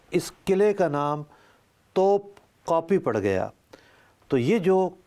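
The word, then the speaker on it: kapı: تو یہ جو قلعہ ہے، اس قلعے پر ایک توپ نصب تھی، اور ترکی زبان میں کاپی، اب اس کے تلفظ میں بھی لوگ غلطی کرتے ہیں، اصل میں کلفی والا قاف ہے.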